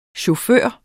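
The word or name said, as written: Chauffør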